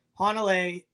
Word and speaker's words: Hanalei: Adam, you don't know how, I mean, I'll tell you later, but Hanalei, is how you pronounce that in Princeville.